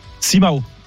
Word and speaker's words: Simão: Quel ancien portugais international était vraiment dans le bâtiment si on prononce bien son nom à la portugaise? – Simão. – Simão.